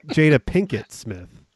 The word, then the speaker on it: Jada Pinkett Smith: Jada Plinkett Smith. – No, for Gotham. – Did you say Jada Plinkett Smith? – Yeah, isn't that her name? – No. It's Jada Pinkett Smith.